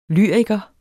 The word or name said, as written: lyriker